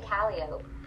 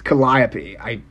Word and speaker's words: Calliope: I think I say Calliope. – It's Calliope.